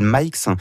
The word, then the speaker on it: Mhikes: qui s'appelle Mikes. Donc, ça s'écrit M-H-I-K-E-S, qui est en fait une version, pour parler comme les jeunes, une version 2.0 des traces GPS.